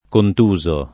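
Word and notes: contuso: italiano

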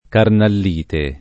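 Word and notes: carnallite: italiano

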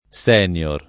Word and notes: senior: lingua italiana

